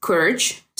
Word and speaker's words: Cuirt: en Galway cada año y el nombre es Curge, C-U-I-R-T. Es una palabra en irlandés, entonces perdónenme si mi pronunciación es rara.